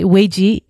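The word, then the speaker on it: Weiji (未濟): So you're going to have to tell me how to pronounce that. It's, mm-hmm. Weiji? – Yeah, that's right. Weiji, yeah.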